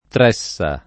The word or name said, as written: Tressa